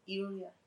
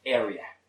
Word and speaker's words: area: Iuria. Area. – Area. Area. In this case, it sounds like an E.